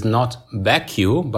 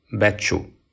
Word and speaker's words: Becciu: Hi guys, since you asked it, the correct pronunciation in Italian for the name of the cardinal is not Becciu, but it's Giovanni Angelo Becciu. Becciu.